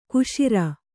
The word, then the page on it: ಕಶೇರು: Kannada